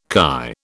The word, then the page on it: chi: British English